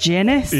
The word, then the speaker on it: Guinness: Okay, are you ready to play Guess the Guinness? Oh, it's pronounced Guinness? – Yeah.